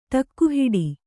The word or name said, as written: ಟಕ್ಕುಹಿಡಿ